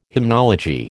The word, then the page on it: hymnology: American English